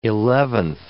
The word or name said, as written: eleventh